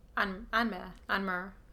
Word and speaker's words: Anmer: But she reached up to the reins of Anmare. I'm now realised I don't know how to pronounce that. – How do you spell it? – A-N-M-E-R. – Anmare. Yes.